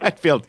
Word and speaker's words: Hetfield: Hetfield, yeah.